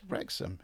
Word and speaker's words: Wrexham: Sorry, I've got to say it right. On a slag heap just outside Wrexham. And it's Wrexham. It says on it. – It says on it, Wrexham.